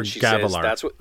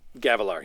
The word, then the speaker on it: Gavilar: Yeah, they're talking about Galabar here. – Yep. – Or Gavilar. I keep saying that wrong. – Gavilar, yeah, King Gavilar.